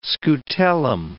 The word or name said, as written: scutellum